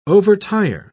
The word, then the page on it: overtire: British English